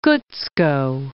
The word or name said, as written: Gutzkow